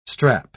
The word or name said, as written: strap